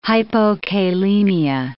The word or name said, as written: hypokalemia